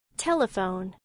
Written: telephone

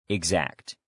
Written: exact

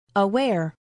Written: aware

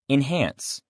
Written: enhance

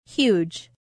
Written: huge

